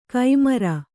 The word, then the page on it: ಕೈಮರ: kai mara